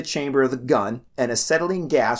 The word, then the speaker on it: acetylene: If you mix calcium carbide with water, it produces a highly flammable gas known as acetylene.